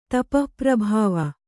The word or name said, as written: ತಪಃಪ್ರಭಾವ